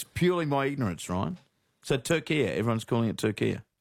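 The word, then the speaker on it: Türkiye: But in yesterday's Socceroos updates, you kept saying Turkey and not... – Turkia.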